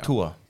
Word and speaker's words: Tua: We pronounce it a different way now. – Tua. – Thank you. – He threw Tua.